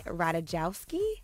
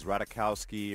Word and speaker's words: Ratajkowski: So Pete Davidson is now dating Emily Ratajkowski. Am I saying that name correctly? – Yeah, I've heard her name pronounced like a couple different ways. Ratajkowski, Ratajkowski.